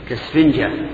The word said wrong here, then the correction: كالإسفنجة: أنا أحسبها ألف، أحسبها همزة، كالإسفنجة هكذا ننطق بها.